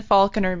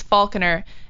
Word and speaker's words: Faulkner: It's MySpace/// Kristen Faulkner Music.